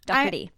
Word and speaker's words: Doherty: Or I know that I think if you're from London or from England, it's pronounced Doherty. Pete Doherty. So Pete Doherty.